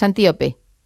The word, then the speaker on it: Antíope: ahondas mucho en la relación de Teseo con Antílope, pero me gustaría no sé, lo he dicho mal el nombre, es Antíope.